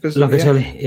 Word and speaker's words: Locatelli: Is that how you pronounce his name? – Locatelli.